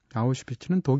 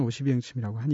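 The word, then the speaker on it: Oświęcim: 아우슈비츠는 독일어 발음이고요. 오슈비행침이 사실은 맞는 발음이겠죠. 폴란드 사람은 오슈비행침이라고 하니까.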